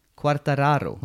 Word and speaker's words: Quartararo: Eh, och sen så var det eh, Marquez på pallen eh, och sen så var det Quartararo och ytterligare en pallplats för fransmannen alltså. Vad hette han nu då? Quartararo.